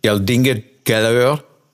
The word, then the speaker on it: Geldingadalur: And it was sending lava down into a valley, and I'll try to pronounce it, Geldingadalur. I don't know how close. That's right.